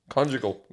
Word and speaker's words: conjugal: Conjugal. Am I saying that right? Conjugal. – Yeah, you are.